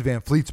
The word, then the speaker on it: VanVleet: Whereas, I mean, Fred VanVleet's been amazing.